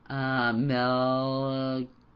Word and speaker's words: Melchizedek: Melchizedek.